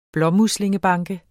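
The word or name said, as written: blåmuslingebanke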